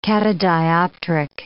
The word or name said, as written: catadioptric